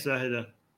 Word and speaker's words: Zahedan: Is it Zahedan? – Yes, Zahedan.